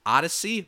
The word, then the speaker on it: Audacy: Remember, that is pronounced Odyssey, but it is spelled A-U-D-A-C-Y.